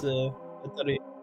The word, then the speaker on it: Guattari: Guattari.